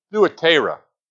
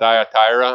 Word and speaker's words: Thyatira: Most people pronounce it Thyatira, I guess. But the U is actually hides the Greek letter there is the upsilon, and so it would have a U sound to it.